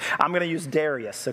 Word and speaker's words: Darius: It's Darius, all right? It's Darius, in case you're wondering.